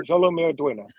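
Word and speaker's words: Xolo Maridueña: Jolo? – Jolo Meduena.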